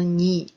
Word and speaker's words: 네: 那这里面呢，其实它的这个读音应该是 네 啊。但是呢，咱们在这个口语当中、歌词当中呢，这个 ne 的发音都会读成 ni。